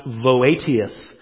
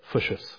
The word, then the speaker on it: Voetius: His name looks like it's pronounced Voetius, but a Dutch Reformed professor told me you say it Fuscius, okay?